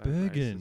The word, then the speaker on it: Steenburgen: B-U-R-G-E-N. – So Bergen.